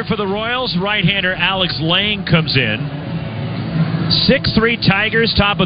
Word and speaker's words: Lange: And now it is Lang, which is spelled L-A-N-G-E.